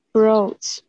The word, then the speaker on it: brooch: brooch brooch br br 不是 pro